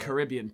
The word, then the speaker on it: Caribbean: It's pronounced Caribbean, first of all, you fucking weirdos.